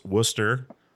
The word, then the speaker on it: Worcester: I did learn to pronounce Worcester instead of Worcester. I got school on that pretty early.